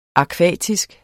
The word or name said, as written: akvatisk